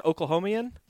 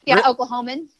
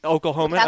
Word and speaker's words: Oklahoman: Is it Oklahomian? – Yeah, Oklahoman. – Oklahoman. Okay.